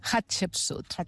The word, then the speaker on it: Hatshepsut: no sé si ho diré bé, Hatshepsut. – Hatshepsut. Molt bé, però ho has pronunciat molt bé, eh?